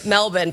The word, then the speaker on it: Melbourne: I don't do that, but I will say Melbourne because I had a bunch of Australians say, thank you so much for pronouncing it correctly after I had a bunch of Americans say, you're American, say it right.